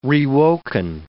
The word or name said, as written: rewoken